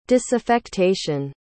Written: disaffectation

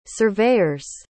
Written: surveyor's

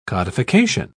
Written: codification

